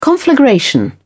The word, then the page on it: conflagration: British English